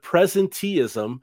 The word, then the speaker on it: Presenteeism: I totally love that term, by the way, and I apologize, I mispronounced it. Presenteeism.